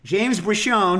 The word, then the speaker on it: Briscione: And we also have with us, and I, by the way, like I checked beforehand, we're doing the Jersey style pronunciation. James Briscione, who's here with his new book, Flavor for All.